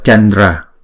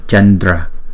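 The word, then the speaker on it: candra: Thì cái cách đọc là Chandra, bởi vì chữ D là chúng ta đọc bằng phần răng, thì cái Anuswara này phải chuyển thành chữ N.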